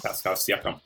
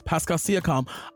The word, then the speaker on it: Pascal Siakam: Am I saying that right, y'all? – Pascal Siakam. Mm-hmm. – Yes, Pascal Siakam, two Cameroonian players.